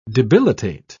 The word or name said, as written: debilitate